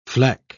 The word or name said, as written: fleck